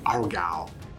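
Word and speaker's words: Aargau: A-A-R-G-A-U. I believe it's pronounced Argal.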